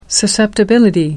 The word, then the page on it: susceptibility: American English